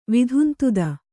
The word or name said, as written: ವಿಧುಂತುದ